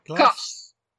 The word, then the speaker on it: kas: So cuss of hobby cuss. – Glash? Is that right? – Cuss. Like K, like in chaos. – Oh, like class. – Cuss. K-A-S. I think it's cuss in English. Just three. K-A-S.